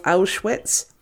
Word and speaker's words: Auschwitz: Sorry if I'm pronouncing the Auschwitz bit wrong. I can never say I can never be sure if I actually say that correctly or whatever. But sounds correct to me.